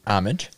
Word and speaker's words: Homage: H-O-M-A-G-E. – Homage.